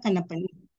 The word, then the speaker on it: Kannappan: Suvetha Kannappan. – Great. Thank you so Kannappan.